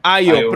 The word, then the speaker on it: Ayo: É, acho que é, é nem é, O olha, botou Jorge, aí, Ayo. – O Jorge falou Ayo, Não sei como é que pronuncia. Como é o nome? Jorge colocou ali, Ayo. – Ayo, pronto.